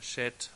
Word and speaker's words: Seth: Und Adam erkannte noch einmal seine Frau und sie gebar einen Sohn und gab ihm den Namen Seth. Und das wird hebräisch Shet oder Shet ausgesprochen.